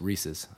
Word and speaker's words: Reese's: I, actually, I'm saying it wrong too. It's Reese's. – yeah. First off, it is Reese's.